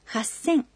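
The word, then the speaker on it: хассэн (八千): Но будьте внимательны, произношение в некоторых случаях тоже меняется. 3000 – «сандзэн». 8000 – хассэн.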